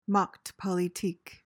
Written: Machtpolitik

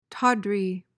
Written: tawdry

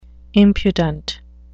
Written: impudent

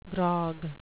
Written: grog

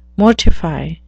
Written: mortify

